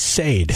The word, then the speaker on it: Sade: Phonetically, this is Sade. – Sade, yeah. I don't know what you said. – Throw an H in there. – It's S-H-A-R-D-E, Sade. I don't know. – Yeah. – I don't know. – I pronounce like Sade. Like don't blame me. – S-H-A-H, then day. Sade.